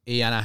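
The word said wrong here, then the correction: Éanna: Harrison. – Aina. – Aina Harrison. – Also, that's Aina is Irish for Enda.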